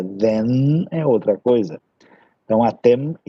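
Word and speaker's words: atem (אתם): Atem. Lembre-se que esse M no final é bem pronunciado, tá? Não pode assim falar atem, né? Atem, atem. Porque o feminino termina com N.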